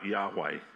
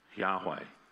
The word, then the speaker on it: Yahweh: In Hebrew, the name is actually made up of four consonants. It's the letters Y-H-W-H. And it's commonly pronounced, because it's actually unpronounceable, it's only consonants, so you can't really say it, but it's pronounced something like Yahweh.